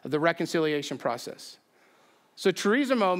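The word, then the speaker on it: Charizomai (χαρίζομαι): Anyone know how to pronounce that? I heard several people say it the wrong way this week. So we'll say it the wrong way right now. Charizomai, of course, is how you say that.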